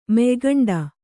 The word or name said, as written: ಮೆಯ್ಗಂಡ